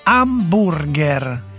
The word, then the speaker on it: hamburger: Sì, ma chi se ne frega, si pronuncia alla tedesca, hamburger.